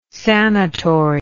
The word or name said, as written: sanatory